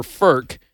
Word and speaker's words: FERC: A lot of people are unaware of this, and it's kind of an obscure agency called the Federal Energy Regulatory Commission, or FERC, is how it's pronounced.